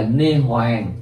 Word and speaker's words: niết bàn: Xá lị phất hoặc là niết bàn có chỗ đọc là nê hoàng.